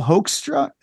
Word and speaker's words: Hoekstra: Joel Hoekstra. Am I saying it right? – I think so.